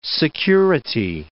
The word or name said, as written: security